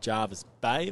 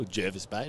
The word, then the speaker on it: Jervis Bay: We head a little bit further north from Bateman's Bay, hearing good reports of fish out of Jervis Bay, or Jervis Bay, depending on your pronunciation.